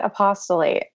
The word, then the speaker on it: Apost(le)ate: I pronounce it apostolate. It's sort of a mashup of the word apostate and apostle, as listeners may have already guessed.